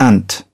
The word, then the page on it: ant: British English